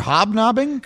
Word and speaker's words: hobnobbing: You were hopsnobbing. Is that what it was? – Hopsnobbing? – Yeah, hobsnobbing. – What? – Obviously, I said it wrong. – What is that? What word are you looking for? Were you looking for hobnobbing? – Correct.